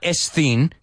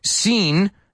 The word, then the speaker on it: scene: Yo sé, S-S-C, dices, scene, scene. No, just scene. Igual que S-E-E-N.